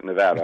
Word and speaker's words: Nevada: More importantly, do you pronounce it Nevada or Nevada? – Well, people in Nevada pronounce it Nevada. I've learned that lesson the hard way.